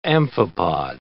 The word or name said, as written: amphipod